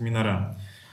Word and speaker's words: минора: Ударение на ада. – Ну, вообще, да, просто, в, по крайней мере, в современном иврите я не очень как бы разбираюсь там вот в древнем, в древнееврейском, но там обычно всегда тяготеет к концу ударение, поэтому они, они по-моему, произносят минора.